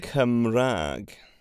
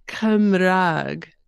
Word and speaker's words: Cymraeg: And so Cymraeg and Cymraeg are both perfectly acceptable ways of saying it. And it just depends on where you are in Wales.